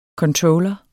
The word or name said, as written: controller